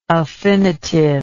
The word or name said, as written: affinitive